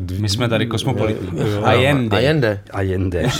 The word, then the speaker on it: Allende: Zpátky, no a asi bych, jestli bys nám řekl něco o osobnosti Salvadora Jendeho. – Tak Kdo Allende, to byl? Allende vlastně, že je... – Jestli jako, to říkám, vyslovuji to Salvador, Ano, je to Allende, ano, Allende.